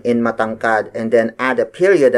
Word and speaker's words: matangkad: Tangkad is spelled as T-A-N-G-K-A-D. It means to have a slim and tall body. As an adjective, we say matangkad. Matangkad. Matangkad. Matangkad is spelled as M-A-T-A-N-G-K-A-D. Again, note the ma syllable at the beginning.